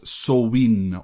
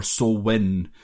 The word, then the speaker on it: Samhain: Is that one of those brutal Gaelic pronunciations, yes, which is like when you read it, it's nothing like the way it's pronounced? – Yes, it looks like Samhain. Yeah. All one word, but like to kind of split it into two, it would be Samhain.